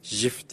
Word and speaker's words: GIF: GIF değil JIF diye e, söyle, telaffuz ediliyormuş.